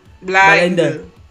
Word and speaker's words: Blind: El próximo drama de Tech Young, Blind, que se estrenaba en la no, Dilo bien, con propiedad. – ¿Cómo se dice? – Blind. – Blind. Así es como lo dicen los.